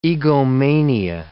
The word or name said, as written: egomania